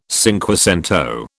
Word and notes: cinquecento: British English

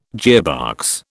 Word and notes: gearbox: British English

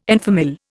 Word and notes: en famille: Indian

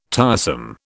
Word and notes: tiresome: British English

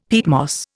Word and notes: peatmoss: Indian

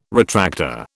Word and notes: retractor: British English

